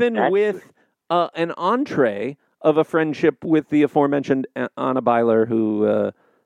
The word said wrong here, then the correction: Anna Biller: or Anna Biller. Anna Biller, I'm sorry, I was pronouncing her name wrong.